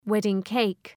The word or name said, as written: wedding-cake